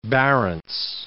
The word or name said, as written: Barents